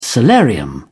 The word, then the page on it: solarium: British English